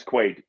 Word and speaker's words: Quaid: which is to get Dennis Quaid laid. Do you think he can get laid? He's Dennis fucking Quaid. – His name rhymes with laid.